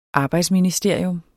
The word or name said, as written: arbejdsministerium